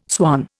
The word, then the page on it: swan: Indian